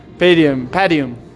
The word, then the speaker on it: Padium: It depends. Different people pronounce it different ways, but the branding team will tell you it's Padium, because it's supposed to be a mix between paddle and stadium. So either should be fine, but...